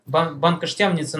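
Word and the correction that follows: Banská Štiavnica: ну, она маленький. Она пишется как это Бянска-Штявница. Да, вот так вот.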